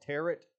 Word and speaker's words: tarot: And they have a version of tarot, you know, it looks like it's spelled tarot cards, but tarot cards, they have their own version of those.